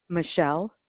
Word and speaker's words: Michelle: Michelle. – Okay. And how do you spell that? – M-I-C-H-E-L-L-E.